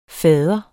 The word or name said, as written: fars